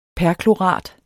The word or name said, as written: perklorat